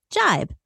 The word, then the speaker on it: gibe: Without further ado, today's word of the day is jibe, spelled G-I-B-E and pronounced jibe.